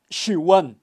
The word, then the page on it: xiwan: Kaqchikel